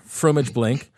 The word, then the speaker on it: fromage blanc: It's pronounced blank. Okay. – Oh, sorry, fromage blanc.